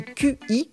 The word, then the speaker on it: qi: Nous avons tous un capital énergétique appelé qi, cela s'écrit Q-I et se prononce qi.